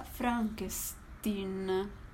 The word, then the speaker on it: Frankenstein: Si pronuncia Frankenstein.